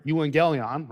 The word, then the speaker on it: euangelion: When we say the gospel, that's a Greek word, euangelion, right? Is how you pronounce it in the Greek, but literally the word means good news, right?